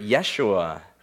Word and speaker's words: Yeshua: Maybe you've heard it, Yeshua is the way they would have pronounced it.